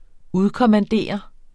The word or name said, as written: udkommandere